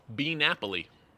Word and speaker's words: Bee Napoli: Bee Napoli. Hmm. Interesting. Is it B period Napoli or is it Bee Napoli? – Bee Napoli. – Spell it. B E. Bee period. Oh, very cool.